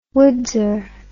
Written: woodser

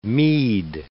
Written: mead

